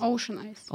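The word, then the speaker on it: Ocean Eyes: Ocean Ice.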